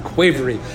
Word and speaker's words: qvevri: Sometimes they're called amphora in English, but they're also called quavery. I'm going to spell it because it's a weird word. Q-V-E-V-R-I, quavery.